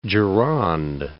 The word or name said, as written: Gironde